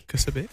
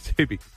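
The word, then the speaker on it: Ksubi: Oh, Subi. – Subi. – Kasubi. – Subi. – With a silent K.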